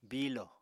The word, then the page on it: biilo: Apatani